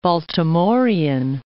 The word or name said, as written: Baltimorean